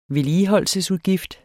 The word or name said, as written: vedligeholdelsesudgift